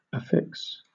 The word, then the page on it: affix: British English